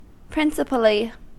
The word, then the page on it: principally: American English